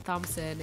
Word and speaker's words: Thompson: And yes, I'm sure to pronounce the H, although I probably shouldn't. But I've had my name spelled incorrectly before. – Really? T-O-M? – Yeah, actually. – Wow. – S-O-N. And yeah, when it first happened, I was like, oh yeah, Thompson is spelled like that sometimes. So I'm sure to pronounce the H and the P.